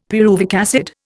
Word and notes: pyruvic acid: Indian

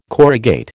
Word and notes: corrugate: American English

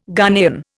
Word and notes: Ghanaian: Indian

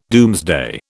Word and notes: doomsday: British English

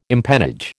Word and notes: empennage: American English

